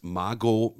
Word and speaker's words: Margot: Man muss sagen, letzte Woche hast du mit Quentin Tarantino gesprochen, mit Brad Pitt, mit Margot, das T spricht man nicht.